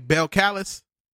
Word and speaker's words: Belcalis: That's how you say her name, Belcalis.